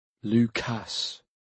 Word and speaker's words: Lucas: Lucas. That was it. Emphasis on the second syllable.